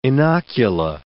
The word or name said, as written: inocula